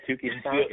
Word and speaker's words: Touki Toussaint: Yeah, Freeman, reliever Will Smith, righty, what is it, Touki Toussaint, did I say that correctly? – Yep, Touki Toussaint, yep.